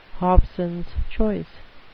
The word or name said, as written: hobsons choice